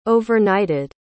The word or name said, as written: overnighted